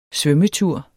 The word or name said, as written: svømmetur